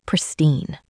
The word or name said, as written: pristine